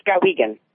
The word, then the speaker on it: Skowhegan: Skowhegan, Maine? Am I saying that right? – Yes, Skowhegan.